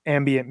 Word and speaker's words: ambient: I think sometimes you ambient. put the emphasis on the second syllable. – Yeah, it's not the am that's wrong. – It's ambient. – You've got to get the emphasis correct.